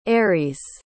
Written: aeries

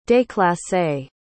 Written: déclassé

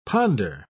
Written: ponder

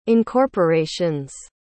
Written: incorporations

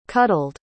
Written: cuddled

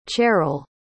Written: cherell

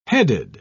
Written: headed

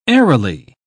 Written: airily

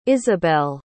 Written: Izabelle

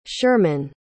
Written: Sherman

